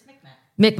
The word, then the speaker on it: Mi'kmaq: It's Mi'kmaq. – Mi'kmaq.